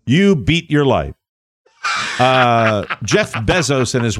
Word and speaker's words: Bezos: By the way, to Beto O'Rourke and Jeff Bezos, may we all collectively pronounce the E as an E? – So Bezos. – Can we say Jeff Bezos and Beto O'Rourke? – Mm-hmm.